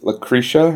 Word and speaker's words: Lucretia: This is Lucretia.